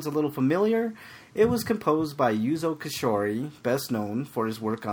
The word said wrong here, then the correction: Koshiro: You totally butchered that name. – Yes, so, he did. – Who cares? – That was horrible. – Koshiro. Yuzo Koshiro.